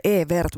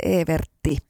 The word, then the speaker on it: Evert: Sehän lausutaan Evert, mutta aina kun mennään tuonne Pohjois-Pohjanmaalle, niin se olisi sitten Evertti.